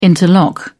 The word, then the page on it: interlock: British English